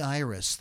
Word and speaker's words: Ierace: And if you're from Pittsburgh, you have to say it like, Thorny Irish. Yeah. Nobody says Donny Iris. They say Thorny Irish. – Born Dominic Iris. I-E-R-A-C-E, Iris.